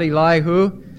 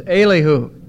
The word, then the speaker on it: Elihu: It's not Elihu, it's Elihu.